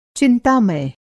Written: चिंतामय

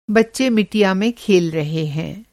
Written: मिटिया

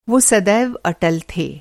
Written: अटल

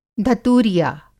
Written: धतूरिया

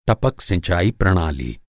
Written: सिंचाई

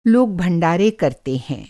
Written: भंडारा

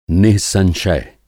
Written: नि:संशय

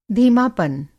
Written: धीमापन

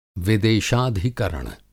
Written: विदेशाधिकरण

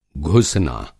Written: घुसना

